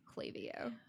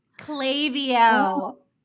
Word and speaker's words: Klaviyo: Just a side note, it's pronounced Klaviyo. – Klaviyo.